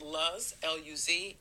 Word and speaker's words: Luz: Now, some pronounce it Luz, L-U-Z. I pronounce it Luz. I happen to know someone who's named Luz, and that name in Spanish at least means light.